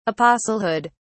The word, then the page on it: apostlehood: British English